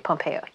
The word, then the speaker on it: Pompeo: Anyway, did you hear about this podcast, Ellen Pompeo? That's how you say her name, right? Pompeo, yes.